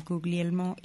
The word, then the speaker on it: Guglielmo: Está con dos jóvenes, a ver si lo digo bien, Guglielmo y Fernando, lo dije.